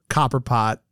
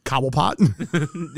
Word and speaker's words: Cobblepot: By the way, unrelated to this, do you know how many times I swap the name Chester Copperpot with Oswald Cobblepot? – Yeah, that works. – I swap that all the time. Did I even say it right? Is it Oswald Cobblepot. – Cobblepot versus Copperpot.